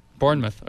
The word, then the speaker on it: Bournemouth: There's an art installation called Real Snail Mail at – I'm going to – butcher this pronunciation, but Bournemouth University, is that right? Bournemouth mouth? In the UK. – Bournemouth, okay.